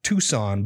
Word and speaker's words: Tucson: It's crazy that it's pronounced Tucson, but it's spelled Tuscan.